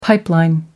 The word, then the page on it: pipeline: American English